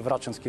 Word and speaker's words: Врачански: Може би е хубаво веднага да кажа, че мнозина вероятно са се очудили, че казах Врачански, но аз, доколкото знам, все още има спор дали е Врачански или Врачански. Предпочетох това ударение.